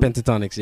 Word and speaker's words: Pentatonix: I saw a group, Pentatonix, I do believe I'm pronouncing it wrong. – Pentatonix, yeah, the a cappella group.